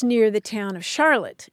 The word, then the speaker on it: Charlotte: But the people who pronounce it Charlotte are in Vermont.